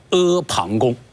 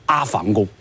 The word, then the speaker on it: 阿房宫: 由于秦朝灭亡以后还没有建造好，还没有给他一个正式的一个美丽的名字，所以天下的老百姓就称呼这个宫殿叫大山旁边的一个宫殿叫阿房宫。啊，这是我对阿房宫的一个解释，不仅是它的词义啊，而且包括它的读音啊，这是需要大家要了解的。你最好不要读成阿房宫。